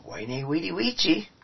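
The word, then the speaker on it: Veni, vidi, vici: And then later he said, and I will give you the more classical pronunciation. He stood on the hill and said, "Wainy, weedy, weechy. I came, I saw, I conquered."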